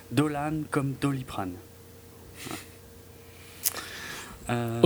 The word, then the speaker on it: Dolan: Alors, Mommy de Xavier Dolan, qu'il faut faire très attention à bien prononcer Dolan et pas Dolan, sinon on se fait insulter sur Twitter par le principal intéressé.